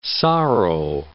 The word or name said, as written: sorrow